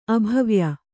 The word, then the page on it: ಅಭವ್ಯ: abhavya